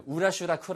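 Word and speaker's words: woulda: 예, 우라슈라 쿠라 이런 게 있어요. 이거 뭐지? 예, 우드브가 우라, 그다음에 쿠드브가 쿠라, 그다음에 슈드브가 슈라.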